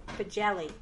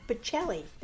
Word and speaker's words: Bocelli: Andre Bocelli. No, that's not right. Bocelli. There we go.